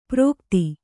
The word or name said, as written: ಪ್ರೋಕ್ತಿ